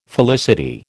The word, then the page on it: felicity: American English